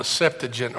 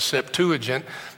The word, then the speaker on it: Septuagint: It's called the Septuagint or the Septuagint. Both pronunciations are acceptable.